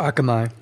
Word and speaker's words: Akamai: And of course, then you have, content distribution networks like Akami, as I don't even know if you say that correctly, but it's, in general... – I think it's Akamai. – Is Akamai, thank you. I thought I was saying that wrong.